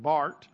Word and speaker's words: Barth: Karl Barth, it's looks like Barth, but it's, I was taught that you pronounce it Barth.